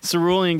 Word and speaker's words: Cerulean: which is going to be at Cerulean Gallery. Am I saying that right? – Yeah. Cerulean. Like the color.